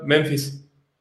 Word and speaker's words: Memphis: No sé cómo se pronuncia Memphis. ¿Alguien me puede corregir? – Así tal cual, Memphis.